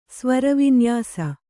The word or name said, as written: ಸ್ವರವಿನ್ಯಾಸ